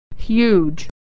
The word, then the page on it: huge: /y/